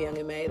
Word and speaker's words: Young M.A: Young Ma and Ari, what's up with that? – Young M.A. Okay, M.A., sorry.